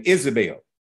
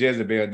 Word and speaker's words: Jezebel: That's the correct pronunciation of her name, Isabel. We said Jezebel. There are no J's in Hebrew, so we dropped them off.